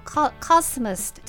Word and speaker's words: cosmos: コスモスは英語でもカスモスに、強く発音してで、マウスって下がる感じですね。カスモス、なるほど、わ、はい、かりました。